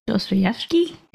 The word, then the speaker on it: Dostoevsky: So like in 1846, Dostoevsky, I can't say his name. You know what I'm talking about? – Dostoevsky? – Yeah.